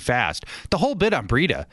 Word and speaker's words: Breida: Whenever healthy, Breida is unbelievable. – And just remember, it rhymes with cheetah.